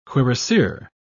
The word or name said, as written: cuirassier